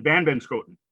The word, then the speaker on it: Vanbenschoten: Matt Vanbenschoten.